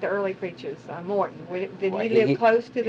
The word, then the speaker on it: Morton: Moulton. – Spell that last name for me. – M-O-R-T-O-N. Moulton. – Oh, Morton. – Morton. – A.B. Morton, okay.